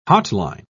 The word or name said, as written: hotline